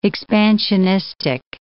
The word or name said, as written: expansionistic